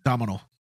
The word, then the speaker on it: Domhnall: Domhnall Gleeson. I hope that's how I say his name. – Domhnall? Yeah.